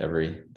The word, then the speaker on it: Everee: Yeah, that's how you pronounce it, Every.